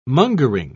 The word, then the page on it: mongering: British English